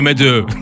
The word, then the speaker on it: Madeaux: Это Meadow, Верно? Да, меду. Правильно я произношу, да. – Но если по-французски говорить, то мэдю Ну, он американец, поэтому как бы типа...